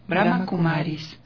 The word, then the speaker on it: Brahma Kumaris: Brahma Kumaris.